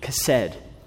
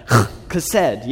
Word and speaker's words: chesed: This word love, if you were to phonetically spell it, a lot of people would like to try to spell it with C-H, chesed. But if you talk to a Hebrew scholar, they're like, no, it needs to be K-H, and you need to put some umph into it, like chesed, you know?